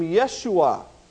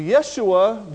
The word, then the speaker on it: Yeshua: If you have heard some of the Hebrew pronunciations of the name of Christ in recent years, you might have heard this name, Yeshua. Well, Yeshua, Joshua, it's the same name in the Old Testament.